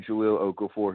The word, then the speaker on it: Jahlil Okafor: Yeah, I think you said it right. It's going to be Jahlil Okafor,